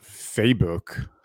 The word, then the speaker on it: Fabok: Jason Fabok. – Yeah. I was recently corrected. I think it's pronounced Facebook.